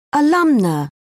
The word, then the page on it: alumna: British English